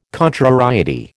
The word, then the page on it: contrariety: American English